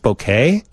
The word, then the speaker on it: bokeh: Oh, it's pronounced bokeh? – Is the aesthetic quality of the blur produced in the out-of-focus parts of an image produced by a lens. – Wow. – Like a bouquet of flowers.